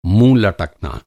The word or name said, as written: लटकना